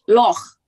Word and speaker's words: Loch: Neither, because they're pronounced Loch.